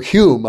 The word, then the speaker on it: Home: And not to mention D.D. Home and uh, or Hume, I believe, is the correct pronunciation,